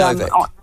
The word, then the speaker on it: Nowak: I just Novak kind of, or just, it's Nowak, yes, but they pronounce it Novak. Right. Is that right? – Yes. Yeah. That's right. – Henry and, Novak.